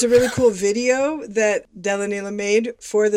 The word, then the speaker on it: Delanila: The band is Della Neela, and that's spelled D-E-L-A-N-I-L-A, sort of like vanilla, but pronounced Della Neela.